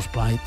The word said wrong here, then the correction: Espriu: No, Espray, no, Espriu, Espriu, Espriu. S'escriu Espriu i es pronuncia Espriu.